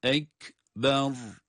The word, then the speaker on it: ekber: Hiçbirisinde uzatma yok. El ekber. Onun için bu kelimeyi okurken asla uzatarak okumuyoruz. Ra'yı da kalın okuyoruz.